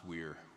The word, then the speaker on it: vir: the Latin root for virtue is a word vir pronounced weir, which means man.